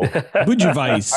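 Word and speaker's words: Budějovice: I used to ferment, I'm going to butcher this. I think it's Budjojevitz or something like that. Strain, it's White Labs. I don't know. – Budjovice. – I've Googled it. Yeah, it sounds like Budjovice, but Booty vice.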